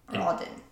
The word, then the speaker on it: Ogden: O-G-D-E-N. Is that Ogden? Ogden. – Pretty sure there's only one way you could really say that. It's Ogden.